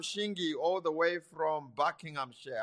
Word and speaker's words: Buckinghamshire: I hope I said it right. I'm not butchering the Queen's language. – You tried. – Welcome. And so what's your question? – Bless you, and bless you. So, yes, you did say it right. It is Buckinghamshire.